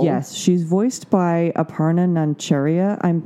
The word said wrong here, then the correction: Nancharla: Nancharla. I'm sorry.